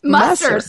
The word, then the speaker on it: Musser: Musser. – excuse me. I needed to put a little doodad over the U, I guess.